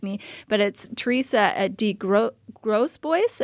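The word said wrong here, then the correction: de Grosbois: Close. It's DeGrosbois. – Oh, Grosbois. Okay, we got a little bit of the French in there. So, Teresa DeGrosbois.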